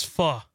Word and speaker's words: pho: Are we saying that right? Is it pho? – It's pho. – Pho.